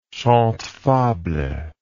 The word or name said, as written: chantefable